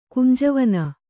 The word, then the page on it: ಕುಂಜವನ: Kannada